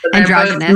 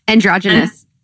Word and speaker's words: androgynous: Androgynous. – Male and female. How do you pronounce that? – Androgynous.